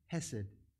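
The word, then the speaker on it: chesed: It starts with a silent C, but it's pronounced hesed.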